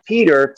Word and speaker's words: Peter: So, oh, my name is spelled Peter, P E T E R D E, capital P, A as an apple, O L A, like O oh, Los Angeles.